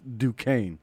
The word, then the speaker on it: Duquesne: But it's pronounced Duquesne.